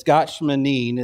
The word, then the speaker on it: Gethsemane: Gethsemane, the word is a compound Hebrew word. It's gatshmanin is how it's pronounced.